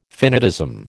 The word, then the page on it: finitism: American English